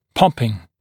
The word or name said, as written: popping